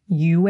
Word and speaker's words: Yue: So the way they were saying it in this show was Yue, and the name is spelled Y-U-E, which is accurate pinyin for Mandarin. But the way that you would say it in Mandarin is Yue.